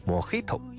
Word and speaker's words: Mojito: Mojito 这种酒呢，中文名字翻译成莫吉托，实际上呢可以算是一种错误的翻译。这个单词呢来源于西班牙语，而单词里的字母 J 并不是读成汉语拼音里的 G，而是应该读成拼音里的 H 的音。 Mojito 啊，应该是这样。